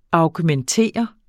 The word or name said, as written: augmentere